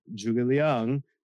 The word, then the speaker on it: Zhuge Liang: they got the Chinese pronunciation right on his name. They did, they went the extra mile. They said Zhuge Liang.